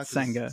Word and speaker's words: Senga: Senga. Oh. Is that how you guys say his name now? – Senga. – Sega Genesis. – It's very close.